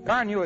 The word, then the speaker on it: Carnu: Car Newel is spelled C-A-R-N-U.